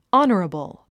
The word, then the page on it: honorable: άnərəbl; アナラブル